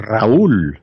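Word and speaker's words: Raúl: Raul and his brother, Fidel, right? – Raul. You have to roll your R's, dude.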